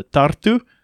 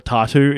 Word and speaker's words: Tartu: So I was staying in the capital for a couple of days before I headed down to Tartu, or Tartu, if you're unable to roll your R.